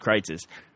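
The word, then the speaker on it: Kreuz's: But this place that we go to eat at Kreitz's, and Kreitz's is spelled Kreuz apostrophe S. – Yes. – It looks like Cruises, but it's pronounced Kreitz's.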